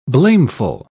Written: blameful

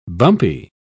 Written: bumpy

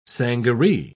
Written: sangaree

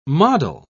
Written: model

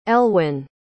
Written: elwyn

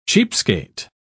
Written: cheapskate

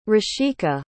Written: rishika

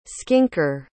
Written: skinker

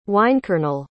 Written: wynkernel

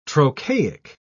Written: trochaic

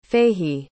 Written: Fahy

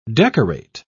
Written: decorate

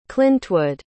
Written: clintwood

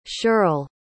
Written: shirl

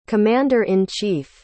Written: commander-in-chief

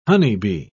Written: honeybee

